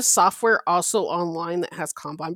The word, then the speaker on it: Kanban: Kanban.